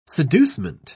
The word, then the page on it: seducement: British English